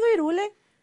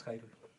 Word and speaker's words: Hyrule: No es cierto, yo más he escuchado Irule. – En español latín sí, pero es Jairul. – ¿Es Jairul, realmente? y, es curioso porque sí, la pronunciación es Jairul o Jairul.